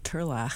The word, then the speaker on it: Turlough: Or maybe I'm not pronouncing Turlough, but it's Turlough. – Turlough.